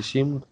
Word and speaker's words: симд: Симк. – Нет, на конце Д, Дмитрий.